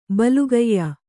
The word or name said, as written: ಬಲಗೆಯ್